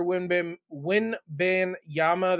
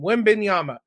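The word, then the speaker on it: Wembanyama: The Spurs are going to get Victor Wimbin Winbin Yama. That's not how you say it. I butchered that name. Wimbin Yama.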